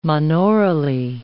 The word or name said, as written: monaurally